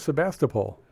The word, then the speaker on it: Sebastopol: S-E-B-A-S-T-O-P-O-L.